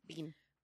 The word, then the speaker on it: Biehn: So this is the original 1984 Terminator, directed by James Cameron, and starring Arnold Schwarzenegger, Linda Hamilton, and Michael Biehn, I'm assuming? – Mm-hmm. – Is that how you say his name? – Biehn.